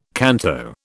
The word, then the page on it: canto: British English